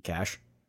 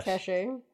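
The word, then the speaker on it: cache: When there's a stock of something, how do you pronounce a C-A-C-H-E? – Cash. – How would you pronounce it? – I say cash. – Cachet. – I would say cachet.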